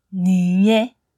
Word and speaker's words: niye: Ni and ye is pronounced as niye, niye, niye, one word.